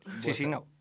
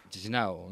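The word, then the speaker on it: Chișinău: Chisinau. – Chisinau, ¿no? O algo así. – Chisinau. Depende de si es más... rumano hablan, es más rumano hablante, o directamente si habla ruso.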